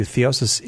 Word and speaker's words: theosis: I do want to point out to you, theosis is a three-syllable word.